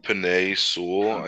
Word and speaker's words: Penei Sewell: Penei Sewell, I believe.